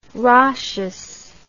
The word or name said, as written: Rochas